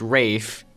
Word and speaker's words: Ralph: So it starts off with one of the sailors of the HMS Pinafore, whose name is Rafe, which is weird because it's spelled Ralph, so everyone gets that confused.